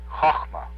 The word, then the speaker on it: Chochmah: Sephira number 2, I'm going to tell you what it sounds like, then I'm going to spell it for you, is Chochmah. That's a hard or a guttural CH sound. The sound Ch is like Loch, the Scotch Loch, you know, like lake. It's like Nacht in German. Ch, Chochmah. C-H-O-C-H-M-A.